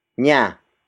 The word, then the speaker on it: niya: Nakain is spelled as N-A-K-A-I-N. It means ate, or the act of eating has happened. The next word is nya. Nya. Nya. Nya is spelled as N-I-Y-A.